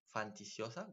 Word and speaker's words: fantasiosa: suena muy fanticiosa fantasiosa. ¿Cómo se dice? Fantasiosa.